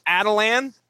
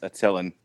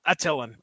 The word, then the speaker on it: Attilan: And I'm going to pronounce this as Adalan. I don't know if you guys are going. – No, it's Attilan. – Now. All right. Attilan. That's what it sounds like you're going to say. Now Now right. – That's how I've always heard it pronounced.